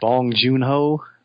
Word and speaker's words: Bong Joon-ho: Is it Bong Joon-ho? – Yeah, that's it.